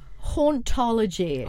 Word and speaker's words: hauntology: So, am I going to pronounce it right? So, hauntology. – Hauntology. – Hauntology. – Yeah, so like haunting, like a ghost. Yeah. And then ology, as in the study of.